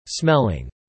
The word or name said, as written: smelling